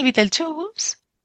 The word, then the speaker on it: Beetlejuice: Pero lo que pasa es que el Beetlejuice, sí pronunciada la inglesa, ellos también hacían el juego con el zumo de escarabajo, ¿no?